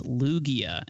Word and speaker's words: Lugia: It's pronounced Lugia, and that is definitely a Pokemon.